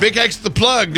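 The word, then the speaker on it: BigXthaPlug: Big X the Plug. Is that how you say it? – Yes. – Yes. So you got Big X the Plug doing his thing.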